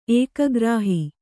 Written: ಏಕಗ್ರಾಹಿ

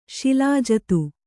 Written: ಶಿಲಾಜಿತು

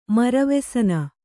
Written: ಮರವೆಸನ

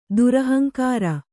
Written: ದುರಹಂಕಾರಿ

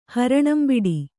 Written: ಹರಣಂಬಿಡಿ

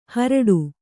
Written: ಹೋರಾಡು

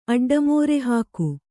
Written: ಅಡ್ಡಮೋರೆಹಾಕು

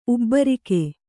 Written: ಉಬ್ಬರಿಕೆ

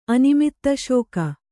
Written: ಅನಿಮಿತ್ತಶೋಕ

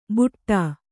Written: ಬುಟ್ಟಾ

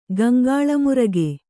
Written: ಗಂಗಾಳಮುರಗೆ

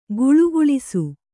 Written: ಘುಳುಘುಳಿಸು

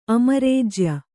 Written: ಅಮರೇಜ್ಯ

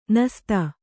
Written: ನಸ್ತ

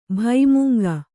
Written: ಭೈಮುಂಗ

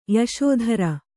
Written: ಯಶೋಧರ

